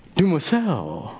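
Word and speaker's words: demoiselle: How did we say? Dumoiseau. It's French.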